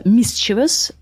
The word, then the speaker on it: mischievous: Yeah, so Cambridge Dictionary says mischievous. So the stress is, on the first syllable.